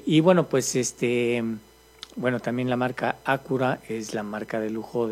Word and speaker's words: Acura: Así como Lexus es la marca de lujo de Toyota, y así como Acura o Acura, así porque el señor español se enoja de que, ay, está mal pronunciado. Entonces, Acura.